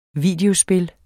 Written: videospil

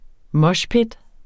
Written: moshpit

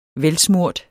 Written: velsmurt

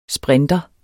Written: sprinter

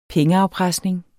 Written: pengeafpresning

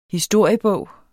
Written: historiebog

